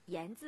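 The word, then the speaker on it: 铅山: 我们的脚步来到江西盐山古镇。说到盐山，其实盐字就是铅笔的这个铅字，在这里呢要读成盐。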